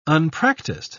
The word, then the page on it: unpractised: British English